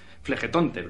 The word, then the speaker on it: Flegetonte: un foso que lo circunvala el río eh, Flegetone, Flegetonte, siempre lo digo mal que es el río del fuego,